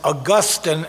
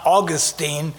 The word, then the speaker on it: Augustine: As I was preparing for this, I was corrected by a theologian on YouTube to make sure I pronounce his name as Augustine and not Augustine, because the Floridians have it wrong.